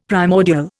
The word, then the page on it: primordial: Indian